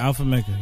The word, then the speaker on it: Alpha Mega: Like, Alpha Mega? Am I saying it right? – Alpha Mega. Yeah.